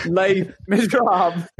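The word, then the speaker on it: Les Misérables: Isn't it Les Miserables? – That could be it as well. That one as well. – Les, as in L-A-Y, it's pronounced.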